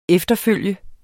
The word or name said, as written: efterfølger